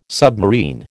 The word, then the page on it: submarine: American English